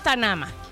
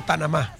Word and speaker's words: Tanama: ¿Es Tanama o Tanamá? – Sí, porque no tiene acento. – Ajá, no tiene acentos.